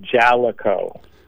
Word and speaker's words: Jaleco: I've been saying Jaleco forever, but I was corrected once that it's Jaleco. How do you say it? – Well, I spent my life mispronouncing names, so I'm not going to ding you too bad. We pronounce it Jaleco.